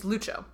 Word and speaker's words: Lucio: His name is pronounced Lucio, but the name as it's spelled is L-U-C-I-O, which is Lucio. – It's Lucho.